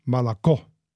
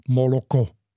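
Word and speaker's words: Malako (молоко): Geschrieben auf Russisch und Ukrainisch gleich Moloko mit drei o Moloko. Aber die Aussprache auf Russisch ist Malako. Also das O wird so reduziert zu einem Ö, Malako. Und im Ukrainischen bleibt das aber nicht reduziert, Moloko.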